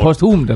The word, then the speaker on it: posthum: og det hedder posthum, eller hvad? Posthum, ja. Okay.